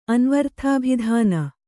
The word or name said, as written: ಅನ್ವರ್ಥಾಭಿಧಾನ